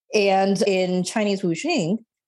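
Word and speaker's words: wuxing (五行): And those 5 parts follow, and please correct me if I'm mispronouncing it. I know that you are from South Korea, or your family is, but this is the Chinese concept of wuxing. Is that correct? – That's correct.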